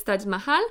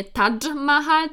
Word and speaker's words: Taj Mahal: grobowiec Taj Mahal, i od razu mówię, że wymawia się poprawnie właśnie tą nazwę, nie Taj Mahal, tylko Taj Mahal,